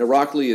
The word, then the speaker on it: Irakli: And Irakli is kind of as it sounds, I-R-A-K-L-I, Alasania is A-L-A-S-A-N-I-A. So it's kind of the simpler spelling. Don't complicate it with additional S's and other silent letters.